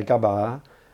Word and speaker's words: gaba: So words which have a long vowel sound at the end, so gaba, over time people say it gaba. But if you ask the old people, they say gaba. And if you say what's lots of them, it's gabas. So the long sound stays there longer when you use the plural.